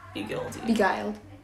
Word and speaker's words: beguiled: Your insolence has begilded you. – Beguiled? – Mm-hmm.